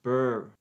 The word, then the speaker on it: Bure: She pronounces it Candace Cameron Bure. – What? – The accent is on the burr. Candace Cameron Bure. – Bure.